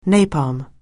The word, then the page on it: napalm: anglais